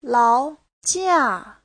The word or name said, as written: lao2jia4